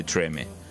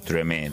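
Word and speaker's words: Tremé: de Treme o Treme, dependiendo cómo lo pronuncies, porque bueno, vamos a encontrar en New Orleans que hay muchas palabras francesas, ¿no?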